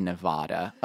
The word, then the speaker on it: Nevada: Yes, I said Nevada, okay? That's how it's pronounced, where we planned to camp.